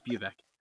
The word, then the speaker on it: Bubeck: It's actually Sean. It's the Irish spelling, so it throws people off. And then the last name is pronounced Bubeck.